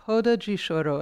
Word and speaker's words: roda de choro: So in Brazil, it's very commonplace to have, it's called a roda de choro, and the R is pronounced as an H.